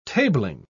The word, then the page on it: tabling: British English